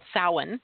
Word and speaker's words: Samhain: It was originally the name of the holiday was called Samhain, which is also sometimes pronounced Samhain. So you may have seen it spelled. It's S as in Sam, A-M-H-A-I-N.